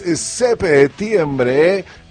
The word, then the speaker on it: septiembre: Y si es 7, es septiembre, con acento en la P.